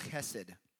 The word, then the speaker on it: chesed: The word loving kindness there is the Hebrew word chesed. That's the last time I'll probably say it properly. I'm going to probably just say it hesed like a white boy that I am, just for the sake of simplicity.